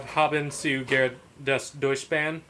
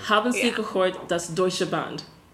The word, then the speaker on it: Haben Sie gehört das Deutsche Band: And then we have Haben zu Gerd des Deutschband, which is a horrible pronunciation of everything. – That's not how you pronounce it. It's Haben sie. Have you ever heard the German band? – Haben sie gehört das Deutsche Band. That's how you say that.